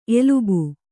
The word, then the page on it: ಎಲುಬು: elubu